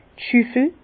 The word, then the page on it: d' Schüffel: Bas-Rhin; Herrlisheim